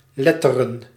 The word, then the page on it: letteren: /le.tə.ɾən/